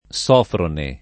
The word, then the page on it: Sofrone: lingua italiana